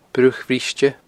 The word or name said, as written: Bruach Bhriste